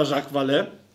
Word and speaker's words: Jacques Vallée: Pisze się przez V, 2L i 2E, a drugie E, o, o, przedostatnie E ma akcent. Więc chyba Jacques Vallée.